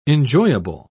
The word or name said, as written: enjoyable